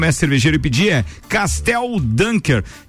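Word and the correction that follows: Kasteel: Eu falei Castel, na verdade a pronúncia pode ser Castil, porque tem dois S, então é Castel Dunker.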